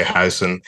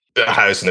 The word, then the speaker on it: Huijsen: But then Huijsen. I mean, and I would say Huijsen. Okay. It's just I guess the way it's spelled. It's U-I-J instead of U-Y. I guess anyway. Huijsen is how I would say it.